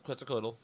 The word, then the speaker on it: Quetzalcoatl: Yep, Quetzalcoatl.